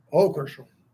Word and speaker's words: Hochul: I actually think Governor, how do you say it, Hochul? – Hochul.